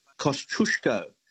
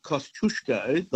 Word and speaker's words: Kosciuszko: There's one Australian place that is mispronounced every day of the week, and that is Kosciuszko, as in Mount Kosciuszko, the highest mountain in Australia, right? Mm-hmm. That's how the Poles would pronounce it, not Kosciuszko.